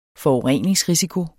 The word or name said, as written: forurening